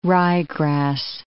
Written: ryegrass

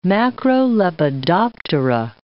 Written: macrolepidoptera

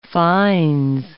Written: fines

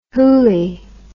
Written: hooley

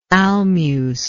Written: almuce

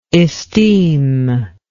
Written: esteemed

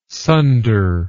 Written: sunder

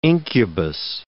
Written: incubus

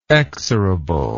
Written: exorable

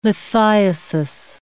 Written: lithiasis

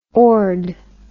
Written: ord

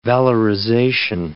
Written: valorization

